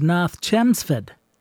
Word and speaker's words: North Chelmsford: He was born in Massachusetts in a city spelled North Chelmsford, but which would be pronounced by my cousins who live in Boston as North Chelmsford.